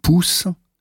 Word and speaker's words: pouce: U wie in Pouce wird ausgesprochen wie U in Musik.